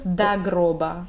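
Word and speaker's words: до гроба: At the beginning of that, we have dagroba. It's also, it's spelled like O, but it pronounces like A.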